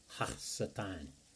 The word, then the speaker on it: Ha-Satan: Ha-Satan is pretty much how you would pronounce that in the Hebrew.